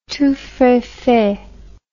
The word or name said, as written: tous frais faits